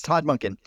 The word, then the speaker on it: Todd Monken: Did I get the name right finally? – Yes. Just in time for him to leave. – Yeah. Yes. Todd Monken.